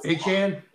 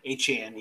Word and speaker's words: Achane: Once they get uh, Arcane or uh, uh Akian back, whatever you fucking say his name, and Waddle. – Achane. – Achane, yeah.